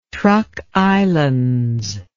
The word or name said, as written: trukislands